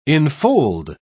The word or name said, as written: enfold